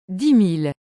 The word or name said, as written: 10000